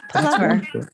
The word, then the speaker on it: plover: And I have always been told that it is a plover, but apparently it is a plover. Like, and the only way that I could remember it is by saying that it rhymes with with Danny Glover.